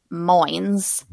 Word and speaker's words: Moynes: So I did figure out how to say his last name. Blake Moynes. – Blake Moynes. – Moynes. I kept like intently listening to hear how they said his last name. So it's Moynes.